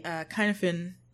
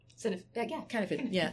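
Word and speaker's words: Cynefin: I'd say that what I learned, I had just been touching on when I joined the team, I had stumbled across the Kinefin model. Did I say it correctly? – Kinnifin. Because I used to pretend and call it the Sinfin. – I think it's the Kinnivan.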